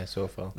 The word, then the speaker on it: Coufal: So our selection for this one is, and I hope I don't mispronounce the surname, but Sofyan Coufal, or Sofal, which I sometimes hear on Match of the Day. – Yeah, Sofal.